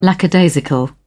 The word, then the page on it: lackadaisical: British English